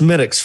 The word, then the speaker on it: Smithwick's: And pronounced Smittix for those at home.